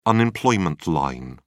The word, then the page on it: unemployment line: British English